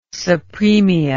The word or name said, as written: sapremia